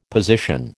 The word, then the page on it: position: American English